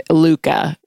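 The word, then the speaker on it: lykke: Luca is how it should be pronounced.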